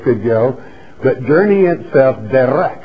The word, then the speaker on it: derek: It's D-E-R-E-K, spelled in the Hebrew. It is pronounced derek, derek.